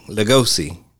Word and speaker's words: Lugosi: I like how um, uh, Lugosi Am I saying it right? – Mm-hmm.